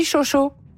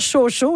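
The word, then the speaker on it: chow-chow: Mais on dit chow chow parce que moi, j'ai toujours dit chocho. – Oui, voilà. Alors la prononciation peut se faire de différentes façons. C'est sûr que c'est le fun de prononcer le W. – Oui, on va dire ciao, ciao. – Tu peux dire chaud, chaud aussi.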